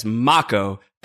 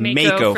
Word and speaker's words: Mako: They run Midgar by having access to what is pronounced officially in FF7 Remake as Mako, though I have said Mako for my entire life. – Yeah. – So I'm going to have a hard time not saying Mako here.